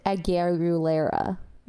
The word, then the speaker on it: Aguilera: I was listening to Christina Aguilera, and I would be an amazing burlesque dancer. – It's pronounced Aguilera.